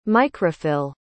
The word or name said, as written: microphyll